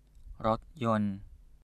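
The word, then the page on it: รถยนต์: Thai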